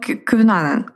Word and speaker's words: Кьюнанан: Я называю его Кьюнанан. Кунанан. Вот. Я знаю, что его еще произносят по-другому, но я буду называть его так, потому что, мне кажется, это максимально близко к произношению на английском языке.